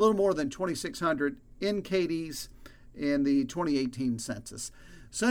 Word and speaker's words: Cadiz: Cadiz is the county seat, spelled C-A-D-I-Z, pronounced Cadiz, and it's a small town.